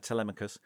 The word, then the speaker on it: Telemachus: from Henry's, that point in Henry's reign, because a little lad, Telemachus, as the play insists you pronounce his name, Telemachus, turns up with a letter saying that he has an ailment.